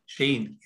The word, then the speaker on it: Cheyne: Sheen, yes.